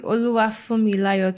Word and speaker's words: Oluwafunmilayo: Hi, Oluwafunmilayo.